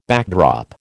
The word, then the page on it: backdrop: American English